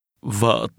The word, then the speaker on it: vợ: Vợ tôi. Vợ. Vợ. Vợ tôi. – In Vietnamese, the word for wife is pronounced with a low, falling, broken tone.